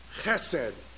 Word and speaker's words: chesed: The word I mentioned last week, chesed. I got to drink some water to say it properly. Chesed. This is your Hebrew word. Say it with me. Chesed. Got it? It comes down here.